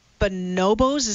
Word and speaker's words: bonobos: you also studied bonobos. Is that how you say it? Bonobos? – You got it. Absolutely.